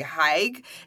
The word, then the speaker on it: hygge: So the Swedish use this term called hygge. I never know how to say it correctly. Hygge. It's H-Y-G-G-E.